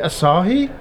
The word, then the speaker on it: Asahi: Is that how you pronounce it? I always called it Asahi. Is it Asahi? – Yes.